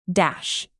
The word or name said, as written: dash